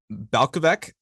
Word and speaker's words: Balkovec: And another thing we saw uh, this weekend was Rachel Balkovec, I believe is how it's pronounced. Yep.